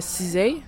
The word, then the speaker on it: SZA: En fait, c'est une chanson euh, qui s'appelle Supermodel de l'artiste euh, Cizay. Donc ça s'écrit S-Z-A.